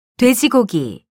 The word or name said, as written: 돼지고기